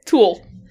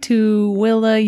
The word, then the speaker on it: Tooele: I was not pronouncing that right in my head while I was doing this. – I am not surprised. – Throughout the entire book. Tool. Tool. – It's Tooele.